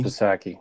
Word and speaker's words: Psaki: Jen Psaki, I screwed this up, but somebody told me Psaki. – Somebody told me how to pronounce it last episode.